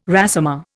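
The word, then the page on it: résumé: Indian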